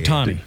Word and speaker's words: Ohtani: Ohtani.